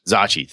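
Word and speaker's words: Zocchi: 30s aren't too funky, but these odd size, these, what Zuchi, Uzuki? I can never pronounce his name correctly. – Zachi. – Thank you. – Believe. – The Zachi dice.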